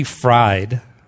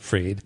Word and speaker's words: Fried: Her name's Nikki Fried, or Freed. I'm sure she pronounced it Freed.